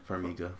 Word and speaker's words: Farmiga: Is that how you say it? Farmiga? – Farmiga.